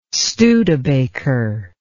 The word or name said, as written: Studebaker